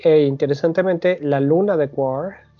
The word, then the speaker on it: Quaoar: QUAR. Q-U-A-O-A-R. Son demasiadas vocales pegaditas y la pronunciación, pues yo le digo quar, ¿tú cómo le dirías? Igual quar,